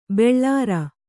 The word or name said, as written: ಬೋಳರೆ